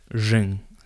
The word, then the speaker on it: Zheng: Because apparently, so her the name's written Ching, C-H-I-N-G, right. – But it's pronounced Zheng. Xing.